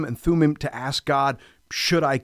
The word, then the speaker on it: Thummim: So to go back to your question, the Urim and Thummim, by the way, great job in pronouncing those.